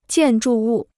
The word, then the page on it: 建筑物: jiàn zhù wù